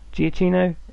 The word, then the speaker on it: Giacchino: And the score is written by Michael Giacchino. Is that how you say it? – Giacchino.